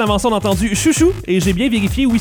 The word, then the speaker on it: Xiu Xiu: Ça se prononce comme ça. – X-I-U, X-I-U. – Oui, bon, ça, oui, ben oui, ben c'est sûr. – Chou-chou. – Hein? Ah, voilà. – Chou-chou. – Chou-chou.